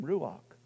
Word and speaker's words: ruach: And they're both pronounced the same, ruach.